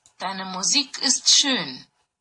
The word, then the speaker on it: Deine Musik ist schön: That's the right way to say it. So, you didn't butcher much of it. I would have said, Deine Musik ist schön. So, very, very alike the way you said it.